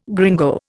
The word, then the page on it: gringo: Indian